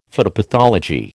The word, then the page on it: phytopathology: American English